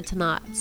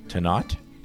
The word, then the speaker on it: Tannat: It's a blend of Cabernet Merlot, Cabernet Franc, Malbec, Petit Verdot, so and a little tiny bit of Tannat. So. – Tannat? Tannat or to be? – Tannat or not Tannat, yes. – Yeah, you said it right.